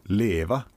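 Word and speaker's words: leva: Leeva on rootsikeelne sõna, hääldatakse tegelikult siis Leeva.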